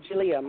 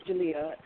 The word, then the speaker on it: Jeliya: I was saying it's Jalia. My name is Jalia, and it's spelled J-E-L-I-Y-A.